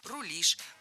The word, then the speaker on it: рулишь: Кроме того, ударение в нашем слове тоже стоит всегда за корнем, как и в начальной форме. «Рулить», «рулит», «рулим», «рулишь».